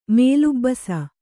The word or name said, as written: ಮೇಲುಬ್ಬಸ